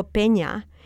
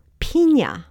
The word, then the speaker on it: Peña: And one day I remember being yelling at my radio because they were talking about the former Secretary of Transportation, Federico Peña, but they pronounced his name Frederico Piña. – Hmm. – Well, a piña is a pineapple, and there's only one R in Federico.